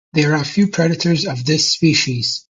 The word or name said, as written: predators